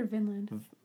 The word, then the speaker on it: Vinland: I know, but I've heard it pronounced both ways. But it was called... I say Vineland because it was named after the vines from the grapes.